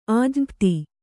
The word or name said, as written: ಆಜ್ಞಪ್ತಿ